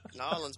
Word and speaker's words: New Orleans: You don't even pronounce that right. Nolans, bro. Nolans.